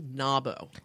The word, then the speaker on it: NAWBO: all one has to do is Google NABO. – N-A-W-B-O. – N-A-W-B-O. And it is, thank you, because we've been using that word, our acronym, and yes, it is N-A-W-B-O.